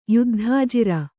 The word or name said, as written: ಯುದ್ಧಾಜಿರ